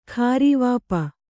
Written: ಖಾರೀವಾಪ

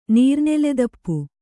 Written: ನೀರ್ನೆಲೆದಪ್ಪು

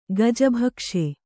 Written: ಗಜಭಕ್ಷೆ

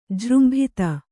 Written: ಜೃಂಭಿತ

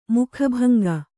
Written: ಮುಖಭಂಗಿ

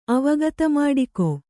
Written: ಅವಗತಮಾಡಿಕೊ

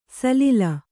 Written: ಸಲೀಲ

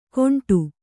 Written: ಗೊಂಡು

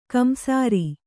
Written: ಕಿಂಸಾರು

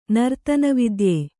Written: ನರ್ತನವಿದ್ಯೆ

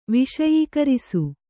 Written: ವಿಷಯೀಕರಿಸು

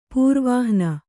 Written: ಪ್ರವಹಣ

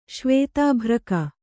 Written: ಶ್ವೇತಾಭ್ರಕ

